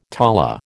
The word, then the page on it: tala: American English